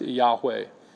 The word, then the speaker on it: Yahweh: The other word for which the Septuagint uses Kyrios is the Hebrew proper name for God, the Tetragrammaton, or YHWH, which is often pronounced Yahweh.